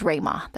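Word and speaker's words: Ruima: You can find us on Twitter at TechBuzzChina and my personal Twitter account is Rayma.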